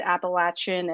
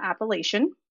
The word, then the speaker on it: Appalachian: But from the south where I lived in Virginia, it is pronounced Appalachian and not Appalachian.